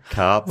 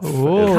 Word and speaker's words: Karpf: Karpf. – Karpf. – Right. How do you spell that? – Lorna Karpf. K-A-R-P-F.